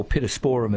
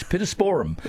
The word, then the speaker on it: Pittosporum: I think the Pittosporum, or Pittosporum as it's more correctly pronounced. – Sorry. Really? – Yeah, no, Australians always say Pittosporum, but we had an English garden lecture, guest lecturer come out when I was at Burnley, and he was outraged. He said it's Pittosporum right. – Because Latin has no emphasis on any of the syllables.